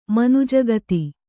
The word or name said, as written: ಮನುಜಗತಿ